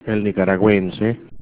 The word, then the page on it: nicaraguense: Spanish